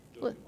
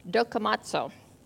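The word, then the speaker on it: Dokimazo (δοκιμάζω): I was going to ask, can you pronounce that word for me? – Dokimazo. – Yeah, that one. Did you hear what he said? Dokimazo.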